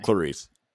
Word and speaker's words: Clarice: Clarice.